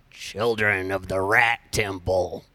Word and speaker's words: Children of the Rat Temple: Children of the Rat Temple.